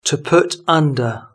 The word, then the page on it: to put under: British English